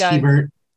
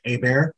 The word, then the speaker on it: Hebert: My last name is Hebert. – I'd love to go. – As you said, my last name is Hebert. It's actually a French name. It's actually supposed to be pronounced Hebert.